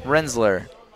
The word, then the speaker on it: Rinsler: B-A-R-R-E-T-T. – There you go. Okay, we got Barrett now. – Okay, and how do you pronounce the last word again? – Rinsler. – Rinsler or Winsler? – Like, Rinsler. – Like Rin, like R. R or what? – Rinsler. – Okay, R. – That's correct.